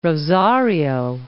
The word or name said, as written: Rosario